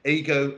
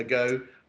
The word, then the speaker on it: AGO: well, uh, we've been called many names, but Ego uh, Ago I, uh, a, like Go, and um, it, it, came about is uh, I was talking to my co-founder Viv Watts.